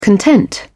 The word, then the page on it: content: British English